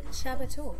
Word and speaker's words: Csaba: And we are joined today by lovely Csaba. How was the pronunciation? – That was very good, actually.